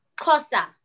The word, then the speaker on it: Xhosa: So tell me how to pronounce the X in Shosha or in. – Oh. That was so cute. okay. It's Xhosa.